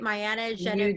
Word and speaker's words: Mayana Genevieve: Today, I am with the amazing Toronto ethical luxury lingerie brand, Mayana Genevieve. Did I say it right? Mayana Genevieve. You did.